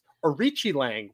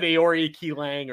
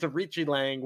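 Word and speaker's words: Aoriqileng: Oh, yeah, I said Aori Ki Lang or something like that. Yes. But it's Arichi Lang,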